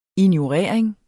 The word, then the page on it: ignorering: [ injoˈʁεɐ̯ˀeŋ ]; [ enjo- ]; [ ino- ]